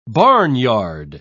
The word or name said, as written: barnyard